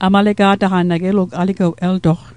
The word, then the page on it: aligew: Mi'gmaq; alikew